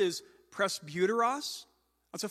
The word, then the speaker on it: presbyteros: That's almost certainly not the way they pronounced it in Koine Greek 2,000 years ago, but that's how English-speaking seminary professors pronounce it, presbyteros.